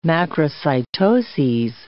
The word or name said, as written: macrocytoses